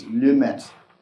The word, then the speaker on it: Lemaître: And to preserve my American tongue, I'm not going to try the proper French pronunciation, which is Lemaître, but I'm going to give you the American pronunciation, Lemaître.